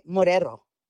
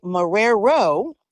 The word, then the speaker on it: Marrero: And she said, no, her name is actually Marrero. She's not Spanish at all. And it's actually pronounced Marrero. And everybody pronounces it wrong and says Morero.